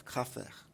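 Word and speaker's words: Kfar: Und die sagen das, sagen ein F, Kaffer. – Yeah, so they pronounce it with an F.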